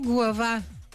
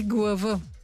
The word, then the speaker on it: Глава: Село Глава. Правилно съм сложила ударението, но някои му казват и Глава.